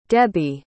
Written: debbi

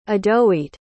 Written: adoeete

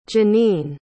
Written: jannine

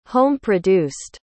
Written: home-produced

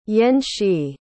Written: yen-shee

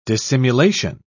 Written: dissimulation

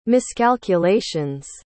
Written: miscalculation's

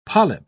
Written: polyp

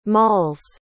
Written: mall's

